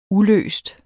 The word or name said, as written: uløst